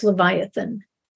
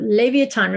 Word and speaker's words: Leviathan: You might have heard it pronounced Leviathan, but Leviathan, remember, he's that great monster of the seas.